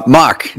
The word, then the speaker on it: Mauch: Uh, Mauch, dude.